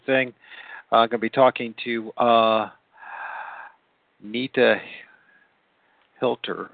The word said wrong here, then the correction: Hiltner: I don't want to butcher her name. Please forgive me, Nita. It's 11 o'clock at night, and I've been being a father all day. Oh gosh. Okay, okay, Nita Hiltner,